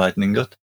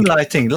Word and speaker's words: Lightning: Nem lighting, lightning. – Nem, lightning. – lighting. – Nem, az lighting. Nem. Uh, nem. – Jó, mindegy. lighting. – Lightning.